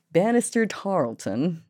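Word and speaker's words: Banastre Tarleton: What's the British pronunciation? Bannister Tarleton. Oh, okay. So I was actually pretty close. Okay, that's cool. All right. I'm learning things. It's cool. All right. Bannister Tarleton captured Boone and several other legislators near Charlottesville.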